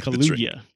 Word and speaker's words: Kaluuya: Also, Daniel Kaluuya, I don't know how to say his last name. – Like Kaluuya. Kaluuya. Yeah.